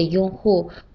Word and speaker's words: 用户: 比如我是苹果手机的用户，不是三星的用户。 – 嗯，非常好，苏卢兰的解释很清楚。那在发音上。拥护和用户的区别在于第一个字，拥护的“ 拥” 是一声，用户的“ 用” 是四声。